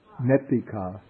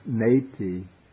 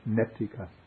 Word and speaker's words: netika: Netika. Yeah. Because there are two T's, so the E, instead of being pronounced neti, it's netika. It's not so sharp, not neti, but netika.